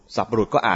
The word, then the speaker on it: สัตบุรุษ: สัตบุรุษก็อ่านยากก็เปลี่ยนเป็นสัตบุรุษไม่รู้แปลงมายังไงนะสะกดว่าสอเสือมนกาตอเต่าแล้วก็บุรุษอ่านว่าสัตบุรุษไม่ใช่อ่านว่าสัตตบุรุษนะไม่มีเสียงตะ